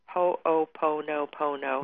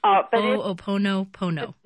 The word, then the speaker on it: Ho'oponopono: Ho-o-po-no-po-no.